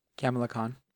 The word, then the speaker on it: Kamala Khan: I don't know how to pronounce her name. Kamala Khan, is it? – Kamala Khan.